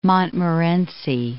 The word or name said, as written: Montmorency Falls